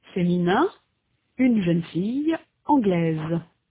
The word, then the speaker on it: anglaise: In sound, the feminine form anglaise has a final consonant z that is not heard in the masculine form anglais. In the masculine, the final s is not pronounced. In the feminine, s represents the sound z.